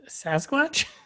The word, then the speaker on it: Sasquatch: Is that how you pronounce Sam Squanch? – I mean, you know, like a humanoid, very hairy, like Bigfoot. I think people say casually. – Say that word again. – Sam Squanch. – I feel like you've given me shit so many times about how I pronounce words. I think this is my moment. You mean Sasquatch? – No, nope.